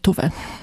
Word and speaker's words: Tove: Wymawia się Tove, pisze się Tove, ale ponieważ ona tutaj była szwedzkojęzyczna, no to tutaj ta wymowa jest Tove.